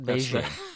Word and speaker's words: Beijing: The English version, I'm not going to try to do Chinese tones, would be Beijing. I'm saying Jing like jingle bells. – Not Beijing.